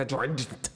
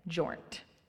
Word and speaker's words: Jornt: I'm like, Jessica Jornt. – Jessica. I always have to say Gornt Candies with a J. Jornt.